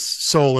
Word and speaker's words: Soule: because both Soule and Browne have a silent E at the end there, which I think is very good and clever.